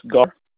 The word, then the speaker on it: Gar: Yes, Gar. – How do you spell that? – G-A-R. – Okay, G-A-R.